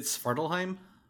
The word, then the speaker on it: Svartalfheim: And so he engages the dwarves of, let me try and say it correctly, Svartalfheim. I know the word, I just can't say it with my tongue. Yes. – Is it Svartalfheim? – Yes. You're correct.